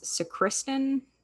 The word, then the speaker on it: sacristan: But Anna's father was a knife grinder and a, I didn't look up how the fuck to say this, sacristan. – Oh, sacristan. – Sacristan. Emphasis on the wrong syllable.